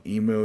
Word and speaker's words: emu: Emu. A little bit of mew in there.